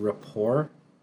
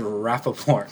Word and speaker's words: rapport: You mean rapport? – What did I say? – He said Rappaport.